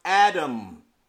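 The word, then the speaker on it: Adam (אדם): Man in the Hebrew is pronounced Adam.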